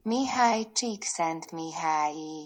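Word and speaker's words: Mihály Csíkszentmihályi: Mihaj Csikszentmihalyi.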